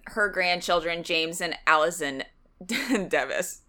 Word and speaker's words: Device: It looks like device, but I think we went with Devis.